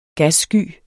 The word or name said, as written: gassky